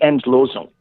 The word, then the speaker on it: Endlösung: And it really shocked me to learn that the phrase, I think it's pronounced Endlösung? – The Endlösung, yes.